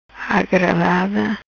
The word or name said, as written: Agradada